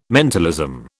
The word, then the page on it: mentalism: British English